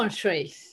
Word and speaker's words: Feng Shui: Feng Shui.